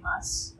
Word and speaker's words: Mads: And Matt, okay, his name is spelled Mads, but he was doing an interview and he said, it's like Thomas without the THO part, you just say Mas or whatever.